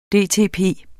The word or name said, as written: dtp